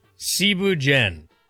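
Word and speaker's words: ceebu jën: First off, Cebu Dien. It's spelled like Cebu Dien.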